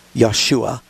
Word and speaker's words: Yeshua: And so that's why the angel said, you'll call his name Yeshua, for he will save his people from their sins. And so if you want to be more literal, that's the way Jesus' name is pronounced, and his name means Jehovah is Savior.